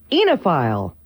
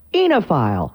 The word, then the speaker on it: oenophile: The word today is enophile. Enophile. Huh? And it's spelled O-E-N-O-P-H-I-L-E.